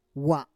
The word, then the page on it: wa: Roon